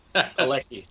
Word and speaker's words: Palicki: Palicki.